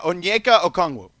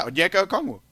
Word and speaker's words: Onyeka Okongwu: Yeah, Onyeka Okongwu. – Really? – Yeah, Onyeka Okongwu.